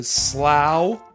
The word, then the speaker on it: slough: ...falls into the slough... – Yeah. – ...of despond... – Mm-hmm. It's spelled like Slough, the town near Reading.